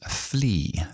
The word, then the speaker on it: flee: Y el último es to flee. Flee, que se pronuncia igual que, pulga, se escribe f l e, e To flee from es huir de.